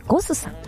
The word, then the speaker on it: GOSS: 提供するというコンセプトで、銀座にシーフードレストラン火山さん、そしてすぐお隣にございますバーレストランゴスさん、 GOSS と書いてね、ゴスさん、そしてお寿司屋さんが 2店舗、お魚のブリにモンと書きまして、シモンというお店が銀座に 1 店舗、日本橋に 1店舗ございます。